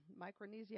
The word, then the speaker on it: Micronesia: Micronesia, am I pronouncing that correctly? Okay.